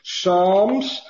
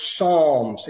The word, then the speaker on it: Psalms: Now, if you'll turn with me to Psalms, it is a silent P, and it is not the palmist, as we heard this week, had to say that. It's Psalms, and it's Psalm 1.